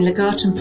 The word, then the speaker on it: Legatum: Hang on, I got somebody here that wants to give you, make sure we got the proper pronunciation. – The 2019 Legatum Prosperity. – So you got it right, yeah.